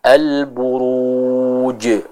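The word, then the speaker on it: Al-buruj (البروج): Yang tepat dia dibaca dengan lebih kuat ya, karena sifatnya syiddah. Al-buruj, al-buruj. Juga dia memiliki sifat jahar sehingga tidak ada udara atau nafas yang keluar dari pengucapan huruf jim tersebut ya.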